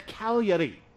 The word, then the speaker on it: Cagliari: And we were about to fly to Cagliari, all right? It's spelled almost like Calgary in Canada, but they pronounce it totally different, okay?